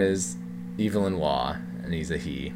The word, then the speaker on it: Evelyn: It is pronounced Evelyn. He was British.